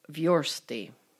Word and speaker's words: versti: The other headache is stress and pronunciation. Singular, versta. Plural, versti.